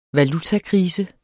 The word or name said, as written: valutakrise